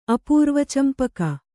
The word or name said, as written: ಅಪೂರ್ವಚಂಪಕ